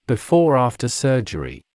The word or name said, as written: before/after surgery